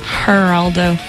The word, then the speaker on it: Geraldo: The G is silent. – Geraldo. – It's not silent, actually, because then it'd be Geraldo. – So it's G is an H. – The G makes an H sound.